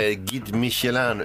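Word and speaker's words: Guide Michelin: Och idag så delar Gid Michelin, uttalar man det så. – Mm. Ja, det var bra.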